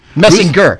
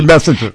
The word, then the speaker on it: Messinger: Messinger. Ruth. That was her slave name, but everybody mispronounced it. – Yeah, right. – And they called her Messenger.